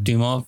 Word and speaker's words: Dumas: Dumas.